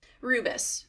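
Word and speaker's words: rebus: Correct me if I'm saying this wrong. Rubus? – Rubus.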